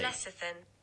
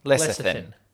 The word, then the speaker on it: lecithin: Oh, it's a soft C. Lecithin. Yeah. – Lecithin.